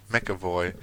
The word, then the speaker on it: McAvoy: Das sagt er in Richtung Xavier und also James McAvoy, wie wird es ausgesprochen? – McAvoy.